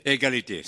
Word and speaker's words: Égalité: Égalité, c'est ça. – With the emphasis on the gui.